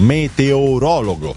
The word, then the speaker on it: meteorologo: Poi un ascoltatore che mi dice meteorologo, che è sbagliato, e si dice meteorologo. Sì, è corretto, io ho detto meteoro, non riesco neanche a pronunciarlo, mi sto intoppando, è difficilissimo. Meteorologo si dice, è giusto, bravo a questo ascoltatore.